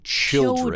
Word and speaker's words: children: The other thing that's very, very important is the pronunciation changes. One child, many children. – Children, the spelling is the same. C-H-I-L-D. But when we say children, it's not children.